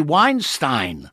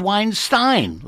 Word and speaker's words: Weinstein: And it's actually Weinstein. Why do they call him Weinstein? But it's Weinstein, like Einstein.